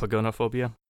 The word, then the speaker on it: pogonophobia: Are you, are you picking up some of Fish's pognophobia? How do you pronounce that? Pognophobia? Is that what it is? – Pogonophobia. – Pogonophobia.